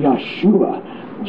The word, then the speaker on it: Yahshua: and Yahshua, Jesus, that's how they say it in Hebrew.